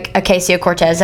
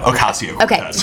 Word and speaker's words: Ocasio-Cortez: But this week, Alexandria Ocasio-Cortez, did I say her name right? – No, Ocasio-Cortez.